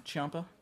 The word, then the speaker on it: Ciampa: Well, obviously Gargano and Ciampa. Is that how you pronounce it? – Yeah, Ciampa. – Yeah. – It's very Italian. Very Italian.